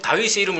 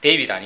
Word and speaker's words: 다윗: DVD만 보고 이 다윗이라고 발음합니다. 예. 데이빗 아닙니다. 이거 그 영어식이고요.